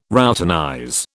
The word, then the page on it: routinize: British English